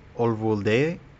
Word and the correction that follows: Uvalde: Olvulde? What is it? – I think it's Uvalde, but whatever.